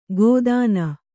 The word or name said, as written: ಗೋದಾನ